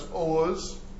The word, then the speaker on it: Oğuz: And then in Turkish, this shows up as Oğuz, with a soft G.